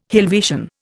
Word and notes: Helvetian: Indian

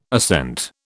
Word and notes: assent: British English

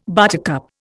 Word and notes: buttercup: Indian

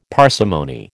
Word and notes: parsimony: American English

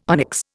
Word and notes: onyx: Indian

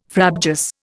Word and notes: frabjous: Indian

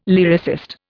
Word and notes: lyricist: Indian

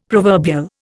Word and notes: proverbial: Indian

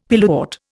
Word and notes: pilewort: Indian

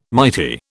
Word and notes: mighty: British English

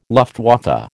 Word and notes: Luftwaffe: American English

